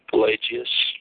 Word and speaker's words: Pelagius: How do you pronounce that? – Pelagius.